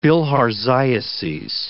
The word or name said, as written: bilharziases